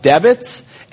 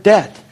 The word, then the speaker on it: debt: And I said, dude, the guy doesn't even know how to say the word debt. He's like, no, it's a debit. And I said, no, Jeff, you don't pronounce the B.